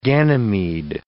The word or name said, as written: Ganymede